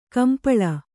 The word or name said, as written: ಕಂಪಳ